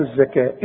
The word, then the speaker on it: الزكاة: الزكاة إيه الزكاة تكتب بالواو وتنطق بالألف وآتوا